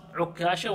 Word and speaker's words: عكاشة: فقام عكاشة بن محصن عكاشة وعكاشة يعني تنطق مشددة ومسهلة.